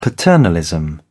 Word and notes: paternalism: British English

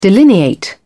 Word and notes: delineate: British English

